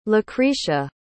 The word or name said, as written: lacretia